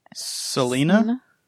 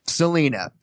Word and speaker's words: Salina: S-A-L-I-N-A. How would you say that? – Salina? – Salina. There you go. We call it Salina.